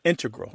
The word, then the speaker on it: integral: Yeah, but both correct, just to clarify here, both correct, but the mathematicians and math-using scientists are more likely to put, say, integral with the stress on the first syllable.